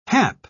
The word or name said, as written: hap